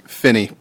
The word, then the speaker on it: Phinney: Kirk Finney, I'm the project manager for the Astoria Station project. – Kirk, can you say your last name again? – Finney, P-H-I-N-N-E-Y.